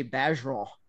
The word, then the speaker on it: Bergeron: And I see them around the country and I'm like, wait, Bergeron, we say Bergeron, you know, or Orgeron.